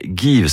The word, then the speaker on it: Geev: Alors notre journaliste Colline Rongère s'est rendue dans une start-up bordelaise qui vient de lancer une appli anti-gaspi qui s'appelle Give, ça s'écrit G-E-E-V.